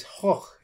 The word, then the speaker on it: Gogh: It's Hoch, isn't it? – Well, it's Hoch. – Hoch. Yeah. – That's how it'd be pronounced in his own language. – Van Gogh. It's not Van Gogh. It's Hoch.